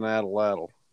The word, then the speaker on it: atlatl: I bet you 40 yards I could kill one with an atlatl.